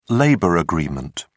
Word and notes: labor agreement: British English

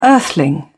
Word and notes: earthling: British English

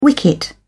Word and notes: wicket: British English

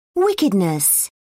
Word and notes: wickedness: British English

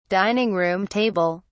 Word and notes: dining-room table: British English

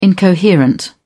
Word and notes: incoherent: British English

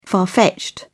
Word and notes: far fetched: British English

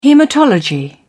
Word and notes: hematology: British English

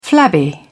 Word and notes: flabby: British English

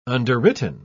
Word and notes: underwritten: British English

